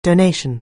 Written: donation

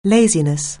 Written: laziness

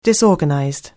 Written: disorganized

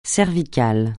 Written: cervical

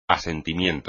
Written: asentimiento

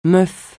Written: meuf

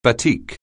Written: batik

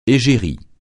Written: égérie

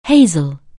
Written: hazel

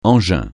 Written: engin

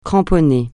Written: cramponner

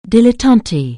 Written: dilettante

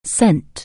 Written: send

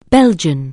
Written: Belgian

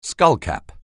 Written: skullcap

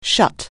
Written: shut

